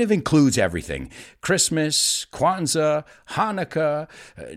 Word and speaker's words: Hanukkah: Those are Jewish people, Jewish friends of mine who celebrate Hanukkah, aunque tenga una CH, se pronuncia Hanukkah, which is an eight day celebration.